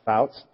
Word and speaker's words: Pfautz: I think it's pronounced Fouts, P-F-A-U-T-Z.